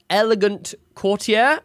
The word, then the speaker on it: Elegant Courtier: Then you get Elegant Courtier. Have I said that right, Jeremy? Did I say a word correctly? – Yes. Yes, you did.